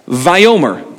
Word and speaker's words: Vayomer: And in English, it would be pronounced something like this. Get rid of that. Vayomer. Give me a Vayomer.